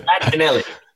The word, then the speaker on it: Battinelli: If you see it on paper, you might not know how to say it. But I've Battinelli.